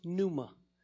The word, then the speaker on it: pneuma: The other one is, it's pronounced pneuma.